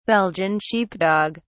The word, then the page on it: belgian sheepdog: British English